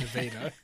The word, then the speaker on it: Nevada: They pronounce it Nevada. And I wrote it that way so that I would remember to say it correctly.